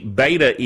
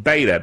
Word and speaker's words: beta: SFTV Beta, B-E-T-A. You know, in England, they pronounce it beta. They pronounce things a little differently sometimes over there.